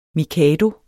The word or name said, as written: mikado